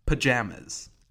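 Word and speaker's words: Pa!jamas!: I think the new one is Pajamas. Pronounced just like that. So it's like P-A, like exclamation mark, J-A-M-A-S.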